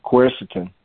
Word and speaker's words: quercetin: I would find some a good source of quercetin.